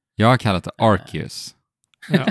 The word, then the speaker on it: Arceus: Alltså på japanska så säger man då Arceus är tydligen den korrekta japanska uttalet. Okay. – Okay. Arceus.